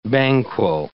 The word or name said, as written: Banquo